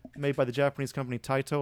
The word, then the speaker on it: Taito: All right, so in 1988, Taito, maybe some people pronounce this Taito, but the Japanese would be Taito, released Superman the arcade game.